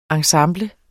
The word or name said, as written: ensemble